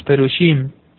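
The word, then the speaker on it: perushim (פרושים): Entre los hebreos, estos eran, así se le conocía a la antigua, más bien en hebreo, así se pronuncia, los perushim, los fariseos, que es un miembro de la secta judía, que en este caso, todas estas ortodoxas eran con rigor.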